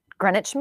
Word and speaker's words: Greenwich: Greenwich Mean Time. Is that how you say it? – Yeah.